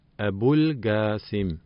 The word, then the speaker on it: Abul Qasim: Wenn man den Namen Muhammad oder dessen Beinamen Abul Qasim nicht aussprechen kann, kann man seinen Beinamen wie folgt aussprechen: Abul Qasim. Hier sei zu beachten, dass das S im Namen Abul Qasim scharf wie das SZ im Wort Straße ausgesprochen wird.